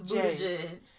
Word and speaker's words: Buttigieg: And Pete, how do you pronounce his name? – Buttigieg, I want to say Buttigieg. – I think Buttigieg. It's Buttigieg.